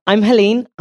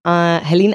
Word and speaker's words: Hélène: I'm Hélène, Hélène Alexi Ioannides.